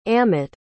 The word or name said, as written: amott